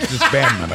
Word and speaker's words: disbandment: You might have been, had an extra syllable there. It's disbandment, I think.